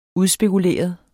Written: udspekuleret

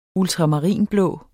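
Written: ultramarinblå

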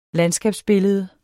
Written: landskabsbillede

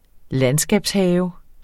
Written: landskabshave

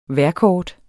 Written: vejrkort

